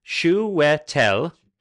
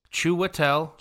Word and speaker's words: Chiwetel: I know how to pronounce it, by the way. I looked it up on IMDb. His name is pronounced Chiwetel Ejiofor. – Ejiofor. – Chiwetel Ejiofor.